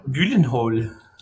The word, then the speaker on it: Gyllenhaal: Das weiß ich, war nicht Gyllenhaal oder Chillenhaal? – Gyllenhaal. – Genau, so spricht man.